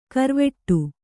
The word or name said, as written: ಗರ್ವಾಟ